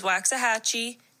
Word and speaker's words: Waxahachie: And here is the correct pronunciation of the five. So one is Green, two is Bernie, three is Nacogdoches, four is Waxahachie, and five is Pflugerville.